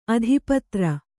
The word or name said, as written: ಆತಪತ್ರ